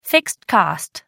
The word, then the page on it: fixed cost: American English